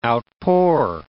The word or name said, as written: outpour